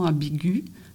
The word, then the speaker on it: ambiguë: Alors, ambigu au féminin, disons une conversation ambiguë, ça s'écrit G-U-E avec le tréma sur le E. Ça signifie « prononce le U qui précède, toi lectrice ou toi lecteur ».